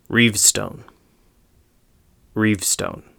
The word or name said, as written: Reavstone